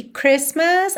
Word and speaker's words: Christmas: Ah, e lembra que Christmas a gente não pronuncia a letra T no meio, então fica Chris, para no S já vai pro M.